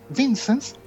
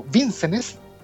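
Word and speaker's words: Vincennes: Está basada en tres personajes protagónicos, tres oficiales de policía del Departamento de Policía de Los Ángeles, el señor Jack Vincent o Vincenes, que eh, según pude ver en la película se pronuncia de las dos maneras, el señor Edmund Hesley y el señor Wendell o Bud White.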